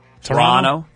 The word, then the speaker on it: Toronto: What's going on is getting ready to take on the Toronto Blue Jays. By the way, the second T you're not supposed to pronounce. – Toronto? – Toronto is how you're supposed to say it, but I always said Toronto.